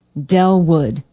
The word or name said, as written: dellwood